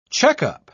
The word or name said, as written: checkup